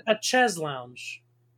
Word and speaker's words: chaise lounge: Chez lounge. – It's a chaise lounge. – The thing that you like lay on. – Yeah. – Oh, I've never said it properly.